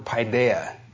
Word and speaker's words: paideia: And let me pronounce it for you. It's the word paideia.